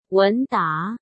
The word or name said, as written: Wen Da